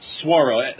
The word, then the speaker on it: Swaro: So the characters are Daredevil, Ghost Razor, Pyroman, and I'm sorry, how would you pronounce it? – Swaro. Just think S-W-A-R-O.